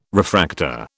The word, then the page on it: refractor: British English